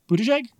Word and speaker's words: Buttigieg: Buttigieg. – Buttigieg.